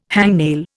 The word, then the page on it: hangnail: Indian